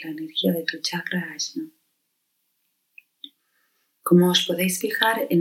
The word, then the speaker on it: Ajna: En la zona del entrecejo está el chakra Ajna, que también se le conoce como Ajna, Aina, ¿sí? Dependiendo de la tradición lo vamos a pronunciar de una forma u otra. Este chakra en el entrecejo es el encargado de todo el sistema endocrino y del sistema respiratorio superior.